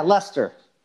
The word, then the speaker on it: Leicester: Yeah, Leicester.